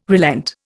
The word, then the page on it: relent: Indian